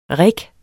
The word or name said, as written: rigge